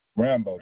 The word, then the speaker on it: Rambo: Rambo, just like the movie. R-A-M-B-O.